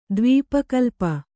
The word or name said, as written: ದ್ವೀಪಕಲ್ಪ